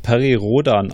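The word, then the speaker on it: Perry Rhodan: Ich glaube, äh, es wird Perry Rodan ausgesprochen.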